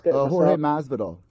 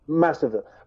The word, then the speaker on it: Jorge Masvidal: Jorge Masvidal. – Masvidal, right.